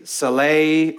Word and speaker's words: Selah: If you do any Google research on how to pronounce that, it's going to say Saleh or Saleh. That feels weird to me because that's never how I learned it, but that word Selah, Saleh, however you want to say that, it's on purpose.